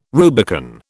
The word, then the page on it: Rubicon: British English